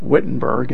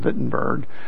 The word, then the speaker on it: Wittenberg: We pronounce it Wittenberg in the United States, but German Wittenberg.